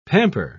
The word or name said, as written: pamper